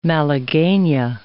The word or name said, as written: malaguena